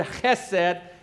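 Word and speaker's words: chesed (חסד): It's built on something called chesed. Okay, if you say that right, something should fly out of your mouth. It's this Hebrew word chesed, and it speaks of a steadfast, loyal kind of love.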